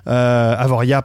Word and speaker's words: Avoriaz: Petite anecdote, euh, j'ai eu la chance de le voir euh, à Avoriaz. Avoria, pardon, il faut pas prononcer le Z, à Avoria dans,